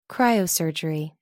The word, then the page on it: cryosurgery: American English